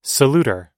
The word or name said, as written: saluter